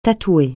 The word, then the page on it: tatouer: francais